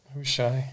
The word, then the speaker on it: Hushai: So, Hushai, am I saying his name correctly? – Mm-hmm. Yeah, that's Hushai, yep.